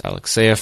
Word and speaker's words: Alexeyev: And of course, you can reach me at the Twitter at Ilya Alexeyev, pronounced Alexeyev for anyone interested, but of course it's spelled weird, so Ilya Alexeyev.